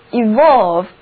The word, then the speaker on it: evolve: 单词 evolve， EVOLVE，这里的字母 E 要读作短的 E，而字母 O 要读作 O。